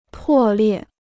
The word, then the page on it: 破裂: pò liè